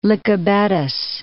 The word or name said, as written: Lykabettos